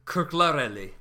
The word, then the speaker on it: Kırklareli: Kırklareli.